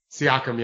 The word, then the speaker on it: Siakam: Siakam? How do you say his name? Um, Pascal Siakam. Siakam.